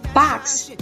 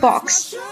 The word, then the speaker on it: box: 美国人将字母 O 读作近似二音的 R，而英国人则将字母 O 读作 O。比如 box，b o x 这个单词，美式读法是 box，英式读法 box。